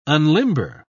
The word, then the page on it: unlimber: British English